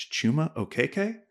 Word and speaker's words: Chuma Okeke: It's Chuma Okeke, and I thank you for letting me know.